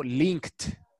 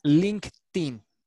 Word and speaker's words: LinkedIn: Como va en participio pasado, link, y le agregué ed, ese ed después de una k se pronuncia como una t. Entonces digo linked. Y luego le pego el in, o sea, LinkedIn. Y si lo digo rápido, LinkedIn.